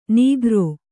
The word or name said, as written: ನೀಗ್ರೋ